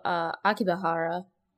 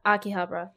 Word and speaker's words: Akihabara: So, but that main episode is strictly about Onoda riding his mommy bike to Akihabara. I said that wrong. – Akihabara, yes.